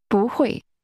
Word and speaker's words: 不会: 不会。不会。 – That's pronounced with first a rising tone, then a falling tone.